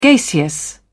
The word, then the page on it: gaseous: British English